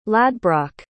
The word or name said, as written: ladbroc